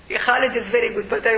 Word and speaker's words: Khalid: When you say, for example, Khalid, do you know how to pronounce this word in Arabic? Kha.